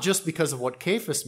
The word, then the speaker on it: Cephas: Cephas, sometimes pronounced Cephas, but when I looked it up this week, yeah, the correct pronunciation is Cephas.